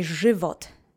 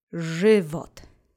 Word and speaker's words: żywot: So now that you have that fresh on your tongue, let's say żywot three times together. Accent is on the first syllable. Żywot.